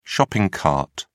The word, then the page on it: shopping cart: American English